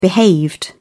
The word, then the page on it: behaved: British English